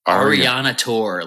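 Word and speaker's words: Arianator: Ariana Tour, like Ariana Tour, like almost like Gladiator.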